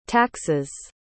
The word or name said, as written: taxes